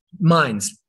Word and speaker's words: Mainz: And is that how we pronounce it? – The city of Mainz? – Mainz. My apologies. Mainz.